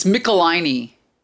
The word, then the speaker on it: Michelinie: So, like you said, it's Amazing Spider-Man 313, and it's written by David Michelinie. Am I pronouncing that right? I'm not sure. – It's Michelinie.